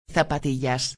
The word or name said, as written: zapatillas